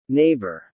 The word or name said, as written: neighbor